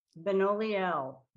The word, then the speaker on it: Benoliel: Benoliel.